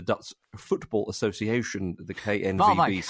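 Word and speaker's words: Waalwijk: I did quite like the bottom of the league side, RK Walwick, I think it's pronounced. – I think it's Valvik, perhaps. Valvik. – I think it's Valvik. – Is it Valvik, is it?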